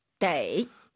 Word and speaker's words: 得: 떠라고 읽을 땐 동사 뒤, 동사 앞에 있을 땐 데이.